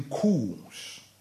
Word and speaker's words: coup: Is it coup or coup? Do you say the P? – No, you don't do the P. – It's coup. Go and watch trends. Anytime there's been coups in every country, the first place the people see is the national television.